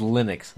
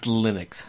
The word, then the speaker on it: Linux: That's pronounced Linux. – Linux. – It's Linux.